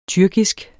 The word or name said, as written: tyrkisk